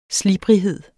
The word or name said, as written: slibrighed